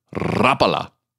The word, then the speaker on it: Rapala: Is it Rapala? Rapala? Rapala? Technically, none of those are correct. Rapala was named for its Finnish inventor, Lauri Rapala. The Finnish word, Rapala, has a distinctly rolled R.